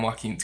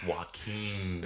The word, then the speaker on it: Joaquin: Yeah, and Joaquin's great.